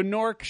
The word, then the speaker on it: Gnork: You know, basically the idea was this gnome named Gnork just kind of I say that because it starts with a G. – Is silent. – Yeah, I'm aware of that. Anyway, so Nork shows up and he basically encases all of the dragons of the world in stone.